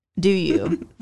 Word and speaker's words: Dew You: I mean, Do You, always. – Thank you. – And it's spelled D-E-W-Y-O-U.